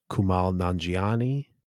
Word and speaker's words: Kumail Nanjiani: I'm going to mispronounce the name, but Kumail Nanjiani. – Awesome. Yeah. That was a good pronunciation.